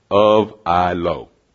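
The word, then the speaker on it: of I-L-O (ὀφείλω): It's the Greek word O-P-H-E-I-L-O. It's number 3784, and it's pronounced of I-L-O. Of I-L-O.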